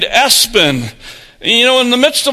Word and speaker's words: ESPN: The name is Espen, or that's the pronunciation. The spelling is E-S-P-N.